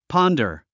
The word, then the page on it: ponder: pάndər; ポォンダー